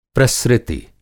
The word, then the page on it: प्रसृति: Hindi